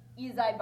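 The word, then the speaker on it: Ysaye: Ezi Barnwell of Sweet Honey in the Rock.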